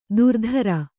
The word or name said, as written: ಧುರಧರ